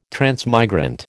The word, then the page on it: transmigrant: American English